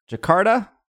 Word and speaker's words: Jakarta: First, we have a Pokemon Go at Pokemon Festival, I'm going to say this wrong, Jakarta? – You got it, thank.